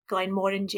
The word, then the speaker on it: Glenmorangie: So a lot of people say Glenmorangie, but it's like orangey, mm-hmm. Orangery, like an orangey, Glenmorangie. Yeah. And they actually changed the packaging to kind of try and emphasize how you say it.